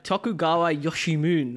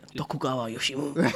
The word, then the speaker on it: Tokugawa Yoshimune: Tokugawa Yoshimune was actually born today. Sake. So his not your best pronunciation, I will say. – Tokugawa Yoshimune. How's that? – So you're away from the Oriental.